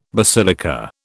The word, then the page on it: basilica: British English